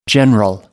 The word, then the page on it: general: American English